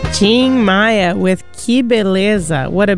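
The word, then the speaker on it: Tim Maia: Ching, it sounds like, well, I'm pronouncing it the Brazilian way, but if you had to say it by reading it in English, you'd say Tim Maya.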